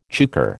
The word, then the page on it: chukar: American English